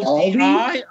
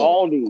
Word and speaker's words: Aldi: Oh, Aldi? Oh, jeez. You need to learn how to pronounce the L. – Yeah, take it from me. – L. – Aldi.